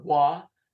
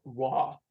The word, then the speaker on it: Roy: Well, I'm pretty sure it's pronounced Patrick Waugh. We're Americans, but Canadians would get on you about that. Just if you're ever up there in the northern part of your state near Quebec and you say Patrick Roy, they might look at you funny.